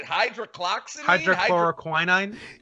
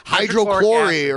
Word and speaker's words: hydroxychloroquine: Hydroxychloroquine? – You know what? Trump doesn't even know how to say it. Trump says hydrochlorine or whatever. He doesn't even say the whole thing.